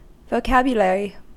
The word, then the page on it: vocabulary: American English